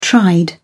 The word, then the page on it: tried: British English